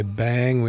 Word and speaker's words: Beyng: And a reminder that when we say bang, we are pronouncing B-E-Y-N-G, which is Heidegger's alternative spelling of being, B-E-I-N-G.